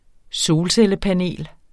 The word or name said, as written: solcellepanel